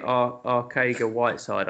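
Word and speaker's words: Arcega-Whiteside: JJ Ariga Whiteside, is it? Ariga? – I did take him. I'm just trying to find. JK Arcega Whiteside, I think it's pronounced.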